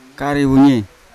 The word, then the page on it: karewunyai: Yawa